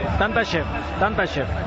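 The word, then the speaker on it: Tantashev: Tantas chef, ¿está bien pronunciado? – Sí, tanta chef,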